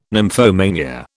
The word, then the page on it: nymphomania: British English